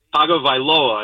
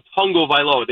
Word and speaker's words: Tagovailoa: Tagovailoa, I believe. But every announcer, they call him Tagovailoa. They throw in an N there that doesn't exist.